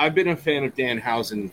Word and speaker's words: Danhausen: Uh, Danhausen, Danhausen, that's how you say it, right? – Yeah. Danhausen, yeah.